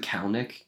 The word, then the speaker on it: Kalanick: Kalanick? – Yeah.